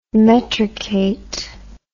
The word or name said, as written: metricate